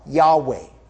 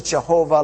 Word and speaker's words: Yahweh: The first one and the most basic name of God, this is actually the name of God, Yahweh. It's sometimes mispronounced as Jehovah. Long story how it got mispronounced. But Yahweh is the right way to pronounce it.